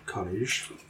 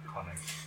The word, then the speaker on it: Konig: Conig. – Conig. K-O-N-I-G.